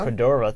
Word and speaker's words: Cordova: Cordova.